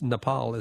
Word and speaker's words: Nepal: We're all saying Nepal until someone, look, many people who are from that beautiful country says Nepal is the way we pronounce it. So that's the only reason I think across the ABC, we all went from saying Nepal to Nepal.